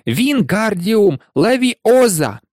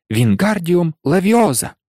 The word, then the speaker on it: Вінгардіум левіоза: «Вінгардіум левіоза!» – вигукував він, розмахуючи своїми довгими руками, немов вітряк. – «Ти не так кажеш!» – дратувалася Герміона. «Має бути Вінгардіум левіоза! Леві треба вимовляти протяжно й легко!» «Кажи сама, коли така розумна!» – огризнувся Рон.